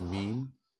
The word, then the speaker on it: mien: The definition showed that it was it's pronounced mean, and it means air or bearing, especially as expressive of attitude or personality, demeanor.